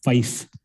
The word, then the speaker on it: feis: He was a perennial winner of, I believe it's called FICE competitions, FEIS, in Derry, which is traditional Irish song and dance competitions.